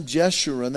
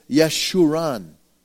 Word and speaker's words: Yeshurun: Now this is neat because I looked this up in the Hebrew, and it's not Jeshurun, that's how we pronounce it. It's Yeshurun. Yeshurun.